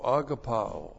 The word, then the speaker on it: agapao: A-G-A-P-A long O. Agapao.